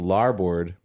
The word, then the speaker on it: larboard: No, so the thing is, the larboard rhymes with starboard.